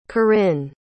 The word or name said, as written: Corinne